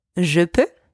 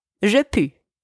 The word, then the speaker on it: je peux: Another thing, it's je peux, not je pu.